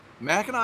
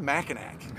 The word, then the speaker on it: Mackinac: You can always tell who's from Michigan and who's not because we have it spelled with a C. So when you get the out-of-towners, like, what's a Mackinac? Like, it's good ice cream. – It's good ice cream. – But the C is silent.